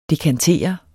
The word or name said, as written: dekantere